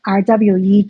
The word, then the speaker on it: RWE: 那上个礼拜三，德国啊、同意让 RWE 啊、它不是德文发音，它是英文发音，让 RWE 这个嗯开采公司去在一个废弃的这个小镇开采。